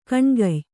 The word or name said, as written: ಗೋನಿಕಾಯ